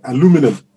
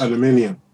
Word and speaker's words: aluminum: And I said before, in America, that would be aluminum, yes. Not aluminium.